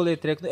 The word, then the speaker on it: Letreco: a pronúncia correta, por favor. Let... A terceira vez você. Treco. – Você Põe um acento. Letreco. Tá Isso, bom.